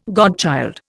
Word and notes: godchild: Indian

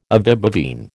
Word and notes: ovibovine: American English

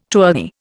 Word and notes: tourney: Indian

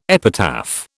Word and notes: epitaph: British English

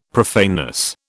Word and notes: profaneness: British English

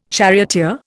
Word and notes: charioteer: Indian

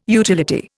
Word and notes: utility: Indian

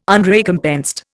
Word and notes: Unrecompensed: Indian

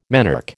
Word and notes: menarche: American English

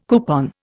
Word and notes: coupon: Indian

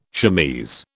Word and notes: chemise: British English

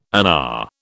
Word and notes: UNA: British English

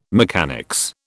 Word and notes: mechanics: British English

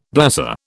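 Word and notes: blasé: British English

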